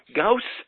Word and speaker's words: Gauss: Gauss rhymes with house.